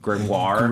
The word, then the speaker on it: grimoire: Were you trying to say grimoire? – There are different ways to pronounce it. In Gargoyles, they pronounced it grimoire. – In sure. Witches of East End, it was grimoire.